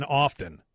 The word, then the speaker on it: often: 630 area code says I could understand people pronouncing the T in listen because people say the T in often. Well, how do you say it? Do you say often or often? – Should be often, right? Often, no T.